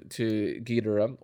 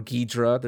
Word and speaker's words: Ghidorah: Yeah, I think the first one you said is actually like the Japanese pronunciation of Ghidorah. – Yeah. – Like with no emphasis. I try to pronounce it that way, but I, you know. – No emphasis on the syllables.